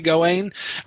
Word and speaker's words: Gawain: So it's been like the French version was clearly Gawain, but the English, the Old English, or the Middle English, you know, especially Sir Gawain and the Green Knight, was clearly Gawain, because it alliterates and the stress is on the first syllable there.